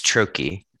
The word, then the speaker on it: troche: Did you say troche or how do you say that? – It's trochee. Um, trochee. Yeah, yeah.